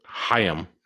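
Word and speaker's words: Haim: Apparently, it's pronounced Hayim, okay.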